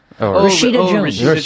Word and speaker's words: Rashida: Rashafia Jones? – Rashida Jones.